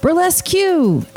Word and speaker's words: Burlesque Q: So then, another employee sticks his head in the doorway of the lounge and yells, Burlesque Q! Anyone here for Burlesque Q? Right this way for Burlesque Q! And yes, he pronounced the Q each and every time.